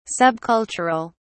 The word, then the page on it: subcultural: British English